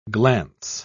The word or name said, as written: glance